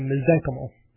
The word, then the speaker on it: mesenchymal: I think it's mesenchymal. – Yeah. – When in doubt, the accent goes on the semi-penultimate syllable. Second to second to last syllable. So mesenchymal. When in doubt. That's always my go-to.